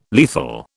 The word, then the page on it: lethal: British English